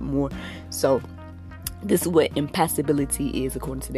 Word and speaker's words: impassibility: And in me asking that, I came across this whole idea of impassibility. Have anyone ever heard of that? It's called, it's spelled I-M-P-A-S-S-I-B-I-L-I-T-Y.